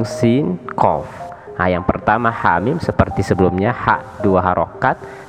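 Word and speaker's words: hamim (حم): Nah, di sini terdiri dari dua huruf, haknya dua harokat, dan mimnya 6 harokat maka cara membacanya hamim sekali lagi hamim nah, jadi sudah ada lima huruf makota.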